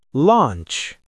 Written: launch

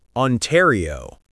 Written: Ontario

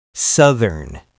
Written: southern

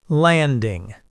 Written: landing

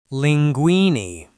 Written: linguini

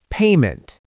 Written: payment

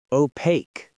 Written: opaque

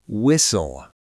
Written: whistle

